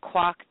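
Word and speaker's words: Kwak: So it's Quack yeah.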